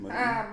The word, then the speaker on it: Merton: Clara's nephew, Merton, I think that's how you say it, Merton, M-E-R-T-O-N, Merton.